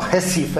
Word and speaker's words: Recife: Further down the coast, this is in the foreground, a town called Olinda, and in the background, Recife, or Recife, as you would say, if you're pronouncing it in Portuguese Brazilian.